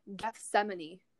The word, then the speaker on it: Gethsemane: Okay, it's just Gethsemane. – Gethsemane? – Yeah. – Gath Oh. – mayonnaise and Gethsemane. They sound so similar.